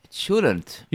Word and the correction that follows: cholent: How do we say it better? – Cholent.